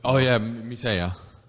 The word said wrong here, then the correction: Mircea: How do you pronounce it? – Mircea.